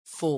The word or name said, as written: faux